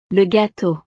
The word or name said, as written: le gateau